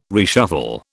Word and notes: reshuffle: British English

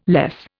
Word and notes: less: Indian

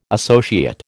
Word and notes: associate: American English

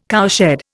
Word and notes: cowshed: Indian

